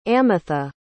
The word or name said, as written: amitha